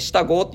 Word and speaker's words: 下郷: まあ下郷って書いて下郷って読みますが、